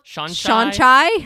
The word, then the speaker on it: Shan-Chai: Is it like It's spelled Shan-Chai. Like, it's actually the name like Sean and then Chai Tea. So it's like Shan-Chai, but a shape-shifting collector of lore.